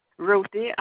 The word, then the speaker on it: Ruthie: Okay, your last name is Benton, B-E-N-T-O-N? – Yes. – Okay. And what's your first name again? – Ruthie, R-U-T-H-I-E. Maybe in Leachman. – Can you spell that for me? – L-E-A-C-H-M-A-N. – L-E-A-C-H? – M-A-N.